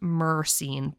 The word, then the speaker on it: myrcene: And I always say myrcene, it's pronounced myrcene, but I always overpronounce the Y and I don't know why.